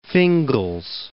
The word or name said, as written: Fingal's Cave